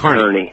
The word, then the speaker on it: Kearney: The pronunciation is Kearney, Nebraska, not Kearney. – Kearney.